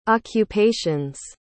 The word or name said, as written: occupations